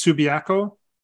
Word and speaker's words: Subiaco: Now, I hope I pronounced that correctly. It's Subiaco? – Yes, that's correct.